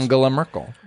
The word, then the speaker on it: Angela Merkel: Angela Merkel. What Mexican president? – That's how you pronounce her name.